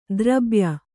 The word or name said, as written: ದ್ರಬ್ಯ